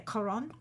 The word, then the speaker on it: Cuarón: How do you say his last name? – Alfonso Cuarón. – Yeah, Cuarón. – Cuarón. – Cuarón. I always say it wrong.